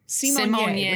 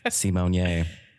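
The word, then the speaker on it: Symoné: Although I did just mispronounce her last name. Simonier. Which she all corrected us on 30 years later. – Wow. Simonier.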